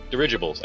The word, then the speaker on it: dirigibles: It takes place in like a steampunk world where you're riding on dirigibles. I believe that's how you say it. – Yeah, that's it.